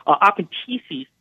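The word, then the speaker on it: apentesis (ἀπάντησις): So post-tribulationists, I think, make a very big lexical mistake when they say, well, the Greek term apentesis, by the way, I use a modern Greek pronunciation, not Erasmian. So apentesis,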